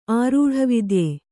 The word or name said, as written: ಆರೂಢವಿದ್ಯೆ